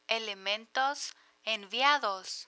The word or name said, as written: elementos enviados